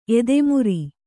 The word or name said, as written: ಎದೆಮುರಿ